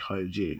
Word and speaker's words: Hergé: Hergé is essentially RG, pronounced like French. Hergé.